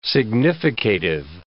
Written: significative

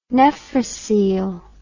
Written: nephrocele

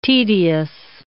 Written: tedious

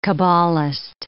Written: cabalist

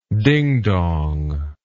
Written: dingdonger

